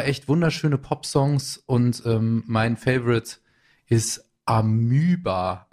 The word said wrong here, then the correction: Amoeba: Amuba, wie würdest du es aussprechen? Die Amöba auf Englisch. Amoeba. Amoeba, ja, danke mal.